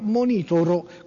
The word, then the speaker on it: monitoro: ma qui si specifica proprio io monitoro, con l'accento sulla I, monitoro esattamente come avevo detto io, magari avventurosamente, bruttissimo io monitoro, però era giusto.